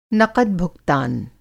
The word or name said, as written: नक़द-भूगतान